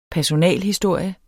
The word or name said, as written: personalhistorie